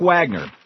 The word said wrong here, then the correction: Wagoner: who is, or Wagoner, I guess it's pronounced, is the CEO of GM.